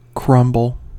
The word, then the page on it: crumble: American English